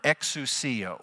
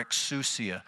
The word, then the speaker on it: exousia: And the Greek word that we're talking about here is a Greek word exousia or exousia, depending on how you want to pronounce it.